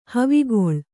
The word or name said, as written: ಹವಿಗೊಳ್